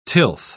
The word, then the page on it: tilth: British English